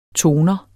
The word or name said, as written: toner